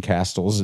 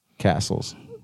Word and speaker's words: castle: castles in the woods and shit like uh-huh. – that. And it was just, but it was. – Did you pronounce the T in castle? – Castles.